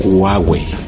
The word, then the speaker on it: Huawei: Huawei. ¿Y cómo debe ser? Huawei. Es una ligera J como cortada a la mitad, por lo que logramos entender.